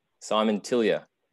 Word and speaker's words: Simon Tillyer: Simon Tillyer, welcome to the World Class Agency Podcast.